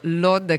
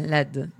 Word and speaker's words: Lod: Tra l'altro ci hanno fatto subito giustamente notare, ci è arrivato subito un suggerimento sulla pronuncia, perché hai detto Lod, che è pronuncia israeliana. Malissimo. – Ben ci bacchettano, giustamente ci bacchettano. – Malissimo. E invece la pronuncia araba è Led, giusto? – Sì.